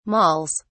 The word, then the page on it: malls: British English